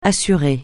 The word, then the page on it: assuré: francais